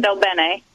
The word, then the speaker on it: DelBene: It's Susan Delbene. – Delbene. Delbene. – Yeah. Del Bene. – I'd call her Del Bean, but that's me.